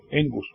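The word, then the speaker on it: engus (ἐγγύς): Se escribe con 2 es, pero en el griego, cuando hay 2 es seguidas, la primera se pronuncia como una n y la otra se pronuncia como la g, engus.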